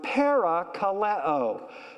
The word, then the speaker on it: parakaleo: And the Greek word is the word parakaleo.